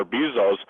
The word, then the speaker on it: Bezos: And so even to the point of everyone's talking now, and I believe that Jeff Bezos of Amazon, or Bezos, whichever way you pronounce his name, is talking about getting off the planet.